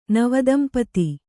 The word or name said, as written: ನವದಂಪತಿ